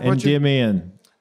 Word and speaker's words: Endymion: So from The Rise of Endymion, am I saying that correctly? – Endymion.